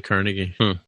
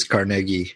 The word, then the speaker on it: Carnegie: Here's how New Yorkers say it, Yeah, they said New Yorkers say Carnegie. – So it sounds like the official Scottish pronunciation is Carnegie, but the New Yorker pronunciation is Carnegie.